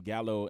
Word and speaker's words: gallo: And, you know, Gallo in Spanish is actually pronounced Gallo.